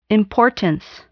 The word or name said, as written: importance